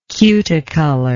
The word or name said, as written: cuticolor